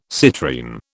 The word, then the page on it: citrine: British English